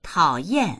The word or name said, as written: tao3yan4